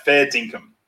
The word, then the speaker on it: fair dinkum: So to spell it for people, fair, F-A-I-R-D-I-N-K-U-M, right? Fair dinkum, not fair, fair dinkum. Income, fair dinkum.